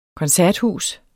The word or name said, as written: koncerthus